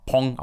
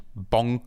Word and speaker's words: Bong: Und auch bei den Oscars haben sie es halt immer Pong ausgesprochen und nicht Bong, wie wir es auch hierzulande lesen würden.